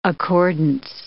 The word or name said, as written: accordance